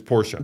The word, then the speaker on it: Porsche: I get your argument, the vernacular is that because that's how it's pronounced in its home country, right? That's how Porsche is pronounced in Germany.